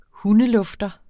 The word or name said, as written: benytter